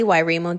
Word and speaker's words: Wairimu: So, um, you can find me um, Kelly Wairimu Davis.